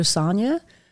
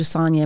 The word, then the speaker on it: Sonja: Follow me on all social media platforms at I Am Dr. Sonia. I Am Dr. Sonia, and my name's spelled with a J, by the way. So S-O-N-J-A.